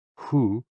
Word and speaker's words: HOO: HOO, pronounced like who, which is associated with the spleen.